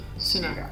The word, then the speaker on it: Sirah: How do you pronounce the other book? – Yeah. – Sirah. – Sirah.